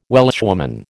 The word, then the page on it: Welshwoman: American English